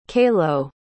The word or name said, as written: caló